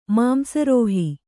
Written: ಮಾಂಸರೋಹಿ

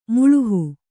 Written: ಮೆಲುಹು

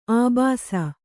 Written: ಅಬ್ಬೆಸ

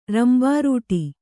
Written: ರಂಬಾರೂಟಿ

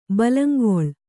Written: ಬೆಲ್ಲಂಗೋಲ್

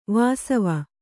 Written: ವಾಸವ

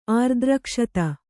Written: ಆರ್ದ್ರಕ್ಷತ

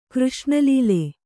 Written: ಕೃಷ್ಣಲೀಲೆ

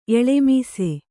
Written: ಎಳ್ಳಮಾಸೆ

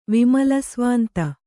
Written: ವಿಮಲಸ್ವಾಂತೆ

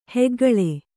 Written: ಹೆಗ್ಗುಳ್ಳ

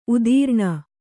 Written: ಉತ್ತರಣೆ

